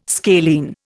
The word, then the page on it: scalene: Indian